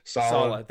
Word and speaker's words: Saleh: The Jets just got, I believe it's Saleh. I don't know if I'm pronouncing that right. – Saleh. They got Rob Saleh.